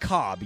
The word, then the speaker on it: Cobb: Well, it's spelled differently, but it's pronounced Cobb, yes.